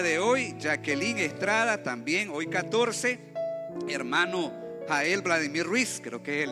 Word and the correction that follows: Gael: Dice que también Gael, perdón, pronuncié mal el nombre.